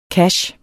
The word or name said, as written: cache